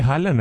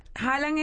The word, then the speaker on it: Haaland: Pero siento que Haaland es como de alar, como Haaland.